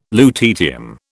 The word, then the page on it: lutetium: British English